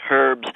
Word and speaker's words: herbs: He pointed out to me that the English correct pronunciation of the Queen's English is herbs, that only those people like Liza Doolittle and who talk Professor Higgins use the word herbs.